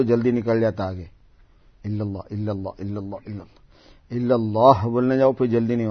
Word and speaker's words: اللہ: یہ تسبیح ذکر کے اندر جو اللہ ہے اس میں ہا کا تلفظ بہت واضح ہونا چاہیے ہر اللہ پر، چاہے آپ لا الہ الا اللہ کہیں یا الا اللہ کہیں یا اللہ اللہ کہیں یا اللہ کہیں.